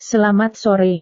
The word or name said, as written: selamat sore